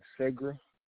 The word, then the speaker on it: Segra: S-E-G-R-A, Sagra.